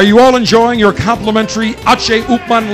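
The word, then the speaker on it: H. Upmann: Upman, or the correct Cuban pronunciation, my H. Upman Legacy.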